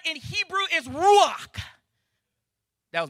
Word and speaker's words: ruach: Ruach, kind of like woo-ha, but ru with like R in the front of it.